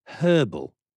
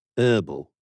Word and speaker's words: herbal: Just to try and explore this hornet's nest with our American guests, the word herbal you pronounce as herbal, right? At one point, was that herbal? – When was the H dropped? When did you guys become question. Jamaican? Because there is an H.